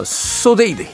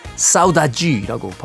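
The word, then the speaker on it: Saudade: 아, 영어로는 어, 소데이드 이렇게 되는데요. 이게 사실은 브라질 포르투갈어예요. 그래서 사우다지라고 발음을 해야 됩니다.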